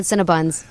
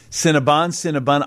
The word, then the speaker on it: Cinnabon: Cinnabon. I believe the correct pronunciation is Cinnabon. Or Cinnabons, if you're talking about more than one.